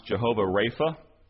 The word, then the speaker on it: Jehovah Rapha: my notes say this is pronounced Jehovah Rapha.